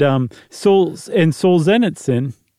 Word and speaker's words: Solzhenitsyn: And Solzhenitsyn, I think I said it right that time. – I think that's right.